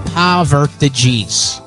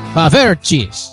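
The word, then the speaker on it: Poffertjes: Yeah, there's a company is accepting cigarette butts as payment for a plate of poverties. I probably mispronounced that. It's all right. Anyways, they're a small Dutch pancake that's usually eaten with a lot of butter and sugar. Pavertjes. Pavertjes.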